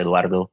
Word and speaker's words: Eduardo: The real name is Eduardo. If you pronounce it in Spanish, Eduardo.